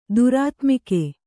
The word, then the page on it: ದುರಾತ್ಮಿಕೆ: Kannada